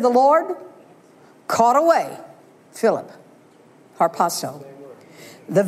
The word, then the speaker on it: harpazo: That's the Greek word harpazo. Rick Renner, I don't know how to pronounce it. H-A-R-P-A-Z-O, harpazo.